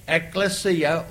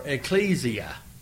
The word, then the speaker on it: ekklesia: The word for the church, for church in the New Testament, the Greek word is ekklesia or ecclesia. You can pronounce it either way.